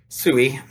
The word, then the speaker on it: SEWE: SEWE, we pronounce it down here in the lowlands.